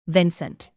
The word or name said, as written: vincent